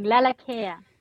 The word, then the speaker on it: Lalakea: Lalakea.